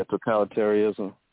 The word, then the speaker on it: totalitarianism: Totalitism? I can't pronounce it. – Oh, yeah, totalitarianism.